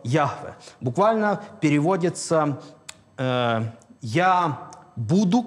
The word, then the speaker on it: Яхве: И э, поскольку это имя для евреев непроизносимо, они от Адонай, Господь, они добавили огласовки, и вот так вышло искусственное слово с двух слов – «Ягова». Но на самом деле большинство э, филологов, э, знатоков древнееврейского языка говорят о, о э, том, что все-таки правильное произношение Яхве.